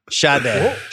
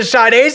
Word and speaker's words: Sade: Sade. Why did I say Sade? Wow. I did the Boston pronunciation.